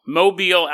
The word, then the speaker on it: Mobile: Mobile, Alabama. The year is 1834. We're going to land the Carpenter Copter Town Square and set on invisibility mode. Now we're walking through the old-timey streets of Mobile, Alabama.